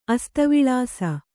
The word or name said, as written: ಅಸ್ತವಿಳಾಸ